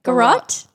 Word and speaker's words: garrote: Is that how you pronounce that? – Garrote? – Garrote? – Garrote, sorry. Garrote.